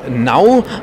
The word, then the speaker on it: Now: No oder Now, man kann es aussprechen, wie man möchte, ist ein immer aktuelles Quiz.